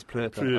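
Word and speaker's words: Pröpper: I would have said proper, but if Werner says it's Prupper, I'm happy that it's Prupper. – It's got an umlauty thing on the O, so...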